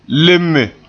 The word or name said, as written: leme